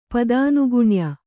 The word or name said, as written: ಪದಾನುಗುಣ್ಯ